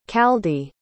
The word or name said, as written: chaldee